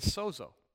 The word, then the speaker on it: sozo: The Greek word for healed here is sozo, or sozo. That's how you say it. Sozo. S-O-Z-O, which we have a ministry called Sozo, which means saved, healed, delivered.